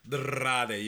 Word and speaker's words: Andrade: Andrade is how they say it. Andrade, there's an R there. Andrade, you got to roll the tongue.